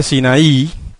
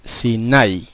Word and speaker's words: Sinai: La mayoría de la gente dice Sinaí, pero en realidad lo correcto en el hebreo, en cuanto a la pronunciación de esa palabra, es Sinai. Se acentúa en la A y no en la I.